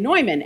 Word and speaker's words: Neumann: Just a brief story about John Newman, and I do pronounce it Newman because I'm from Philadelphia and that's where he was from and that's how we say it, even though it's technically probably, you're correct, and Neumann, and it's not the same as Cardinal John Newman, who'll be canonized in just a few weeks.